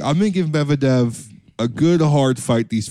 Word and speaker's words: Medvedev: Sinner won versus Medvedev.